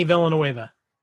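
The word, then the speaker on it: Villeneuve: But love the filmmaker, Villanueva. Is that how you pronounce his name? – Yeah, Denny Villanueva.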